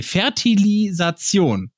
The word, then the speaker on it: Fertilisation: Und dann ein Jahr später, äh, ja, 1982 die erste Deu äh, das erste deutsche In vitro Fertilis Fertilisatz Nee, Fertilisation. Richtig?